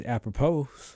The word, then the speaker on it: apropos: Seems apropos. – This is okay. – Apropos. – Yeah, there's an S at the end. A-P-R-O-P-O-S. I guess the S is silent.